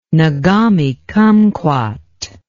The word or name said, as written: nagami kumquat